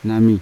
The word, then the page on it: nami: Yawa